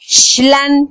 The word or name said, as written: xlan